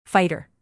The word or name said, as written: fighter